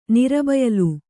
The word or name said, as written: ನಿರಬಯಲು